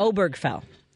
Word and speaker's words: Obergefell: Jim Obergefell. Obergefell.